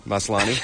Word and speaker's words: Maslany: Maslany.